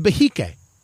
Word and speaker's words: Behike: The Behike, B-E-H-I-K-E, sometimes referred to as the Behike. I pronounced it wrong just the other day.